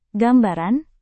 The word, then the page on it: gambaran: Indonesian